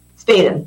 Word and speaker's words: Spadin: Spaden.